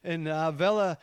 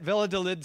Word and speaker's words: Valladolid: In Valladolid, that's how you say it, Valladolid, Spain, where Christopher Columbus died in 1506, there stands a monument commemorating the great discoverer.